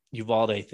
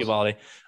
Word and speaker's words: Uvalde: I don't really want to get into the Uvalde thing. Did I say that right? – Uvalde. – Close. Uvalde.